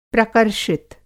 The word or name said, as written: प्रकर्षित